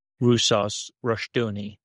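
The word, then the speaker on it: Rousas Rushdoony: There's no accent in Armenian, so the correct pronunciation would be Russus Rushdoony.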